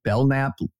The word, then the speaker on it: Belknap: Belknap, if you're going to pronounce it Belknap, spell it B-E-L-N-A-P, not B-E-L-K-N-A-P.